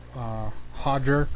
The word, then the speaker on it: Roger: Roger Gracie, or Hodger Gracie, because they don't pronounce the R's. They pronounce it with an H down there in Brazil.